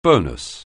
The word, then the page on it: bonus: anglais